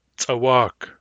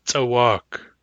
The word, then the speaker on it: tsawak: Hard C, tsawak, tsawak. In Nunchaknoth, tsawak has a long vowel in the second syllable and it is stressed on the second syllable. Tsawak. Short long.